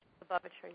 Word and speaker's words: Lubavitcher: Lubavitcher, yeah.